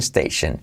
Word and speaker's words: station: So I'm going to pick up my friend at the train station, at the, la T de at tampoco se pronuncia, at the, semiclave TH, at the train station. Cuidado la S de station y la letra clave SH, station, alright?